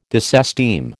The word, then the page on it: disesteem: American English